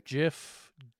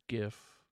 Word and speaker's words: GIF: GIF. GIF. – I'm going to give, jeez, man, GIF or GIF. I'm going with the hard G, but again, his wife's name is Jennifer, so I just think GIF and Jen is just so damn cute.